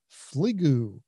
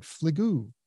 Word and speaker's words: Fligoo: Broadridge has announced with proprietary software or with an AI firm called Fligoo. I'm saying that right. Fligoo. F-L-I-G-O-O.